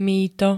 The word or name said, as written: mýto